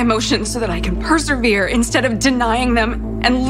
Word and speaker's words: persevere: Można go wymówić na dwa sposoby. Brytyjczycy powiedzą persevere, czyli zaakcentują trzecią sylabę.